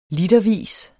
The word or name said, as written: litervis